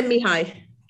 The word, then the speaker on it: Mihai: I learn best when I am in that Chickaman, the Mihai, I can never pronounce his name, the guy who wrote the book on flow. – You say Mihai. – Yeah, good. Yeah.